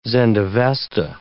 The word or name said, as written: Zend-Avesta